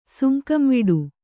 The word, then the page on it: ಸುಂಕಂವಿಡು: kannada